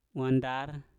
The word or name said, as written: wandar